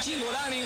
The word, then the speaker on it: Cingolani: Si es italiano, debería ser Cingolani, porque la CI se pronuncia como Chi. Ustedes lo saben, ¿no? – Me gustó mucho la pronunciación, como es Cingolani.